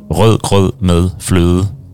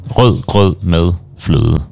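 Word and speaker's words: rødgrød med fløde: Usually, if you want to test people's pronunciation in Danish, you have to say the name of a dessert called rød grød med fløde, because it's all these like ø and d sounds, like there's five of them in like one small.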